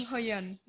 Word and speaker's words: Jung Ho-yeon: Jung, hoy lo dije todo mal, perdón. Jung Ho-yeon. – Jung Ho-yeon. – según yo. Jung Hoyon. – Jung Ho-yeon. La actriz de Squid Game, que es como la más recordada por todos eh, los que vieron Squid Game.